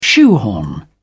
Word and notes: shoehorn: British English

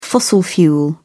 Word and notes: fossil fuel: British English

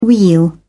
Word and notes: weal: British English

